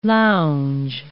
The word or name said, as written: lounge